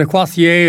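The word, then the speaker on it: Corsaire: The Corsair, or the Quassier, if you want to pronounce it in French, that wonderful concert overture written by Hector Berlioz many, many moons ago in 1844, and the Corey Band doing the honour.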